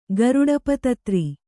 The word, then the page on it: ಗರುಡಪತತ್ರಿ: garuḍa patatri